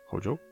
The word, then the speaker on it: Hojo: Og hun er jo da en nøkkelperson i det spillet, og eh, du har eh, en karakter som heter Hojo. Ja, om jeg uttaler det sånn? Hojo? Ja. Eh, Hojo kalte jeg den bare.